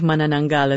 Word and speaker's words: Manananggal: And I got to say, that your pronunciation of Manananggal is quite impressive.